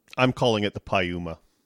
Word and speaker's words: Piuma: And then there's also Uma Thurman, and her name is spelled U-M-A, so Pi Uma. So to me, it's the Piuma. – Yeah. – Good to me. – Yeah, but you have to say it right. I mean, it's Piuma. – Piuma.